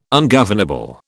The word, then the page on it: ungovernable: British English